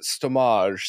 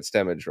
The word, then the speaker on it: Stemage: Knowing my luck, you are actually you pronounce it stomage, so I'm glad I got Stemage right. – No, I like saying I, say, I like saying it's garbage, but with a stem.